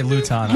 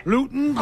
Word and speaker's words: Luton: How do you say his name? – I say Luton. – Luton, Leeton, Louton, Lobin. – Luton. – Probably like Newton. – All right. – Luton.